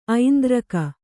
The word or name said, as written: ಐಂದ್ರಕ